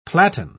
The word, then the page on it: platen: British English